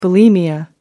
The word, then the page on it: bulimia: American English